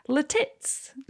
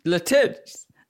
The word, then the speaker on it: Lititz: The actual name of the city is Lititz. – Lititz.